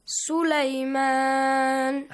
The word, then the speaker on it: سُلَيْمَان: When you stop, Sulaiman. Make a longer sound, yes? Read it. – سليمان.